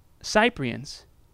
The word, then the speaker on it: Cyprians: What about the Cyprians? And yes, I looked that up. That's how you say it.